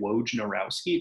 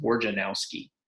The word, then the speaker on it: Wojnarowski: Adrian, as it turns out, his last name pronounced Wojnarowski. I've been pronouncing it incorrectly all this time. I've been saying Wojnarowski. It turns out it's Wojnarowski.